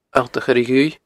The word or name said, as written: Allt a Choire Dhuibh